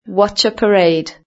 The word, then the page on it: watch a parade: British English